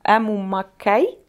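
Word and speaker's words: Емма Маккей: Е, І мені дуже сподобався момент е, з тим, що е, в цей фільм е, взяли е, Ему Маккей, здається, так вимовляється її е, ім'я.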